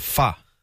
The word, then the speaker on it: pho: It's because I've always called it pho, but someone told me it's called pho. – Yeah, right. – Is it called pho? Yeah. – But I thought if I said that, no one would understand what I'm saying. – But it's spelled P-H-O.